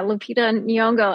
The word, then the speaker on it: Lupita Nyong'o: Yeah, Lupita Nyong'o.